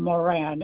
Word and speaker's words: Moran: Barbara Moran. – Miss Barbara Moran, and what county do you reside in? – I'm in Philadelphia. – Okay, and Moran is spelled Murran. – No, it's spelled Moran, Moran.